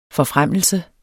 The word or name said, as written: forfremmelse